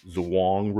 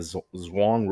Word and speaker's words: Zhurong: But uh, its rover is the Zhurong rover. I'm sure there's not two syllables in that. Z H U R O N G, as Sean Esquire pointed out.